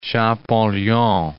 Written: Champollion

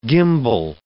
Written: gimbal

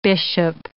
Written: bishop

